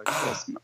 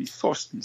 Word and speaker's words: Thorsten: Genauso wie bei dem Namen Thomas wird das H nämlich bei Thorsten auch still ausgesprochen. Mhm, aber okay. In der Tat, es gibt durchaus viele Amerikaner, die Thorsten sagen, was mir dann immer die Haare zu Berge stehen lässt.